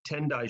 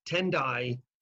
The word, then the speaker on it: Tendai: Tendai is the same character as Tiantai. It's just pronounced a Japanese way instead of a Chinese way.